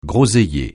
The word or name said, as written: groseillier